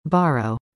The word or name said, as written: Borrow